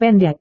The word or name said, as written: pendek